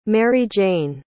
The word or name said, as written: maryjane